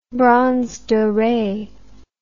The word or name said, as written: bronze dore